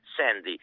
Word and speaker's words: Sandy: dicía eu, Sandy, imolo pronunciar como dobrador de Travolta, Sandy,